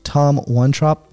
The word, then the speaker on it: Tom Wontrop: Tom Wontrop.